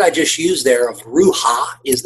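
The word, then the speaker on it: ruha (רוח): And it's pronounced in a way that sounds breathy. It's ruha. And it's intended to exhale, to give in the breathy sound.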